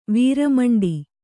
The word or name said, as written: ವೀರಮಂಡಿ